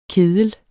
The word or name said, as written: kedel